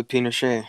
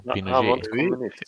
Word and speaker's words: Pinochet: Augusto Pinochet. – Ja, precis. Ja. – Jag vet inte hur du uttalade det. Det var lite fransk accent på den. – Ja, Pinochet.